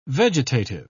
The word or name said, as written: vegetative